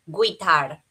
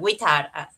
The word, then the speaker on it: guitar: En inglés, muchos pronuncian la palabra guitar, ¿no? Tal como en español, guitar. Pero en realidad se pronuncia guitar, ¿no? He plays the guitar every day. Recuerda, la U está de vacaciones. No le invites.